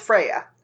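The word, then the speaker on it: Freyja: Freya? Is that a hard J? – No, it's Freya. – Okay, so mm-hmm. The J is silent.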